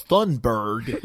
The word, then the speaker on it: Thunberg: It sounds so extra ignorant to hear the way Americans pronounce her name after hearing the correct pronunciation. – Yes, it's Greta Thunberg.